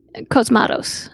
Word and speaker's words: Cosmatos: Cosmatos.